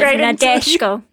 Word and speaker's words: Nadeshiko: Nadeshiko.